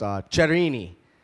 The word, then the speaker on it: Cherini: I found out recently my name is actually pronounced Cherini. It's very Italian.